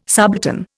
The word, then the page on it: subaltern: Indian